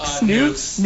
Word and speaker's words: Nux: Also, if you look in the paper and see N-U-X, I don't know how you pronounce that. Nux? – Nukes.